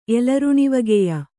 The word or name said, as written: ಎಲರುಣಿವಗೆಯ